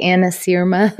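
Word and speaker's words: anasyrma: What is known as Anasarma, right? Is that how you pronounce it? Anasarma? – I say it far more Oklahoman. I say Anasirma.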